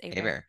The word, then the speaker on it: Hebert: It's Hebert.